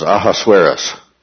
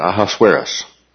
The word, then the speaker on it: Ahasuerus: And the Hebrew pronunciation of that, because the pronunciation we have comes from the Greek, the Hebrew pronunciation was Ahasuerus.